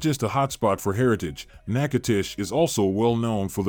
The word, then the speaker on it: Natchitoches: In Louisiana's oldest city, Natchitoches, pronounced Natchitoches, you can celebrate, discover, and explore the destination of travelers since 1714.